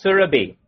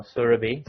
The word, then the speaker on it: Surabhi: Is it Sur? I don't think it's Surabhi. It's Surabhi. – Surabhi. – No, not Ra. Not Surabhi. – Ah. – It's a short A.